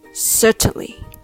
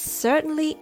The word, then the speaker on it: certainly: Certainly has three syllables. Sir, Chen, Li. Make sure you pronounce the R sound. Sir, sir, sir, sir. Next syllable, Chen, is very short. Chen, Chen. Certain, certain. And you can just add ly at the end. Certainly. Certainly. Please also note many people just skip the T sound altogether and say certainly.